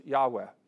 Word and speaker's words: Yahweh: So there's four Hebrew consonants that we often pronounce Yahweh, though we're not exactly sure what the vowels that go with those four consonants are.